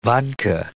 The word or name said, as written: vodka